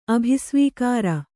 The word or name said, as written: ಅಭಿಸ್ವೀಕಾರ